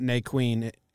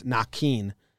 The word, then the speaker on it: Naquin: It's not Naquin. Naquin. It's Naquin. And then the reporters went and like checked, and they're like, oh, it's Naquin, not Naquin.